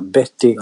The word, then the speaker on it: Bette: Ah, vet ni att hon förresten hade fått sitt namn efter Bette Davis? – Nej, det visste jag inte. – Ja, okay. Fast de uttalar namnen olika. Betty Davis och Bette Midler.